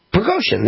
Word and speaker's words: Prigozhin: Yevgeny Prigozhin? What'd you say? How do you pronounce the last name? Prigozhin. There we go. I got it right.